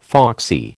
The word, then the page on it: foxy: American English